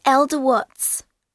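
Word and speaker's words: Elda Watts: Hi, my name's Elder Watts. – How do you spell that? – My first name is E-L-D-A and my surname is W-A-T-T-S.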